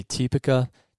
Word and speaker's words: Typica: And so the two big ones you're going to see if you were to see this on a bag is you'll see Typica, T-Y-P-I-C-A, or you'll see Bourbon, which is spelled like bourbon, but Bourbon.